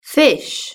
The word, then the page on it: fish: British English